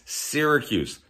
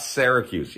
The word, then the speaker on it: Syracuse: It's Syracuse, not Syracuse.